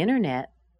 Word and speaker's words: internet: Internet is a noun. The I-N, the first syllable, is where you hear the most stress. Contact, there's another verb. Con, the C-O-N, is what we're stressing.